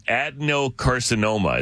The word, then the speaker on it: adenocarcinoma: Adenocarcinoma is how it's pronounced.